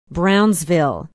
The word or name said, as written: brownsville